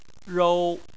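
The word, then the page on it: rou: tone 1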